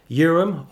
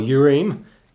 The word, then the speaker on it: Urim: Urim or Urim, it can be pronounced differently, Thummim or Thummim.